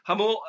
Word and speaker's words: Jamul: That's spelt with a J, for those of you who don't know how to spell that one. But Jamul, California, and there's a hole in the fence right there.